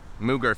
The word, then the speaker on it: Moog: Mooger Fooger. Right? – Yeah, but their name is pronounced Moog.